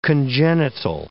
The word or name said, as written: congenital